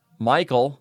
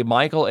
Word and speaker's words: Michel: Michael, that last name is spelled M-I-C-H-E-L, of Hills. Marianne A. Michael, age 85, died peacefully Thursday, January 5th, 2023 at Atrium Village in Hills.